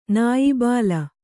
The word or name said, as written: ನಾಯ್ಬೇಲ